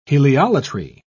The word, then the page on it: heliolatry: British English